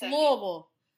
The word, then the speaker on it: Louisville: In our hometown of Louisville, not Louisville or Louisville. – God, I hate when people say that. It's Louisville. Louisville. Louisville. That's how you say it, guys.